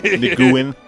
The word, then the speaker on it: Nguyen: Nguyen.